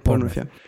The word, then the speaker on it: Bournemouth: But like the last two, it was the Aston Villa match and the Bournemouth. I don't know how to properly pronounce that city. – Yeah, Bournemouth.